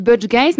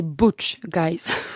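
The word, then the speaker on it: Butch Guice: Alors moi, je dis Butch Guys, mais apparemment, c'est Butch Guys.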